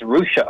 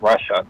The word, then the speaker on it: Russia: Yeah, and then I think it's Russia, Ohio, not Russia.